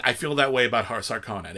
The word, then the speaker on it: Harkonnen: Harkonnens. They changed the pronunciation. – In David Lynch's, it was Harkonnen. – Yeah. – In this one, they say Harkonnen.